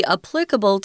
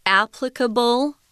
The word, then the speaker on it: applicable: It's got two different pronunciations. Uh, some people say applicable applicable. Uh, I say applicable. It's easier. Mm. Applicable. I think one of the hard things about English is just knowing where the zhongyin or the the the heaviest syllable falls.